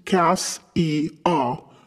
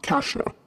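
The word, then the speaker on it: Cassia: I am requesting that you, the author who has the authority to do this, would please officially change the pronunciation to Cass-E-A, not Kasha.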